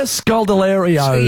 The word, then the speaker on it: Scodelario: It's Kaya Scodelario.